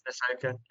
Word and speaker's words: Ahsoka: They're watching Ahsoka. Is that how you say it? – I'm watching Ahsoka, yeah.